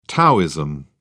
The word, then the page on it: taoism: British English